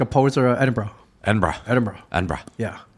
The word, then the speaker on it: Edinburgh: I was pronouncing it Edinburgh. – It's Edinburgh. – And I'm still not saying it right. Okay. Edinburgh. He's Edinburgh. – Like, drop the oro. Drop everything. Edinburgh.